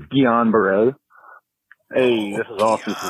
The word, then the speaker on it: Guillain-Barré: Believe Guillain-Barré. – Hey, fellas. It's JJ. I'm the surgery resident at UVA that listens to the show. I'm pretty sure it's pronounced Guillain-Barré.